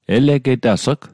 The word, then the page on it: elegetas'g: Mi'gmaq